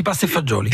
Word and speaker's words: pasta e fagioli: Euh, la pasta e fagioli. Ah, ça. J'ai bien prononcé ou pas Si, si, pasta e fagioli.